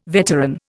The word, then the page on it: veteran: Indian